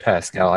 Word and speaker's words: Pascal: You did say his name Pedro Pascal instead of Pascal. I just needed like solid proof. – Pascal. – Spelled with the C.